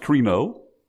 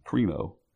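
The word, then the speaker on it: krino (κρίνω): It's a form of the word judgment, which in the Greek would be pronounced krino. That's what's in verse 5, krino.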